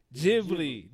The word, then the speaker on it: Ghibli: How say the name for Ghibli. – Me? Ghibli.